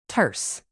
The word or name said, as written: terse